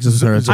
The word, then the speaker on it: Zathura: He wants to correct your pronunciation. – It's actually like Zathura.